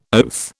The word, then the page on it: oath: British English